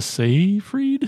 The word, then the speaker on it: Seyfried: It was on Netflix, and it has Amanda Seyfried.